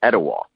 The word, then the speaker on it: Etowah: I saw you were born in is it Etowah, Tennessee? – Et, et, you you pronounce it well, Etowah.